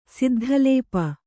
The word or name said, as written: ಸಿದ್ಧಲೇಪ